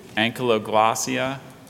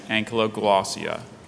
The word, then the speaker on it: ankyloglossia: There is just the two, ankyloglossia and ankyloglossia.